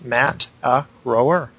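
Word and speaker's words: Mataroa: So it's, thanks for the mention in episode 14, but Matt Aroa, a Polynesian word, is pronounced Mat-a-rower.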